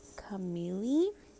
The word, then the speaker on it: Camille: The first one is I believe it's pronounced Camille. It's spelled C-A-M-I-L-L-E. The last name is Colazo, C-O-L-L-A-Z-O, and she is a YouTuber that talks about how to save money, how to, like, have side hustles.